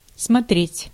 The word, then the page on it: смотреть: /smɐˈtrʲetʲ/; /'smʌtrʲеtʲ/